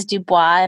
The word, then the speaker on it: Dubois: Because my last name, mine's Dubois, and I get like Duboisy and Duboisy and all different variations of that.